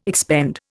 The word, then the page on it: expend: Indian